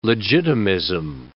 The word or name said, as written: legitimism